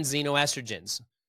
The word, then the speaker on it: xenoestrogen: You can use ChatGPT and type in xenoestrogens. List me sources of xenoestrogens, X-E-N-O, estrogen, E-S-T-R-O-G-E-N, xenoestrogen. Sounds like a Z, but it's an X. It's like Xavier. Right? It's spelled with an X.